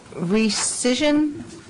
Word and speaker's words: rescission: Just to restate, and so people at home or picking us up would understand, rescission, is that how you pronounce it? – Yes.